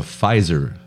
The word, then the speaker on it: Pfizer: What they're saying is that in one of the vaccines, it's called the Pfizer vaccine, PFIZ. Okay. There's a silent P.